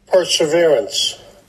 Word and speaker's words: Perseverance: Ich glaube, die häufigsten Alternativen war Perseverance und Preservance, die ich gehört habe. Aber für alle, die es uns nicht glauben, dass wir es richtig aussprechen, hier kommt einmal so klingt es.